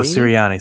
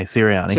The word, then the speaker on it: Sirianni: Um, and that's, that's kind of well, I, I think Serenini, is that how you say it? Nick Seren, Serenini? Uh, uh, Serini? Siri- Oh, Sirianni. – Sirianni. Sir- oh, Sirianni. Thank you.